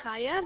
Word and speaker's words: Chaya: My name is Chaya. That's spelled C-H-A-Y-A.